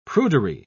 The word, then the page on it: prudery: British English